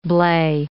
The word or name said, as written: blae